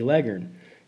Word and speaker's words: Leghorn: It's spelled L-E-G-H-O-R-N, Leghorn, but everybody knows that it's pronounced Leghorn.